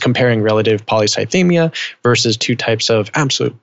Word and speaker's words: polycythemia: But when you have something like the image that you sent me, which is got relative, am I saying that right? Polycythemia? – Polycythemia.